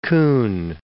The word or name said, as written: Coen